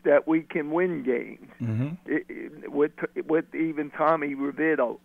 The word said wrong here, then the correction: DeVito: or what, how you say it? – DeVito. Yes.